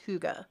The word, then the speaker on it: hygge: but it's pronounced like hygge. – Yeah, it's H-Y-G-G-E.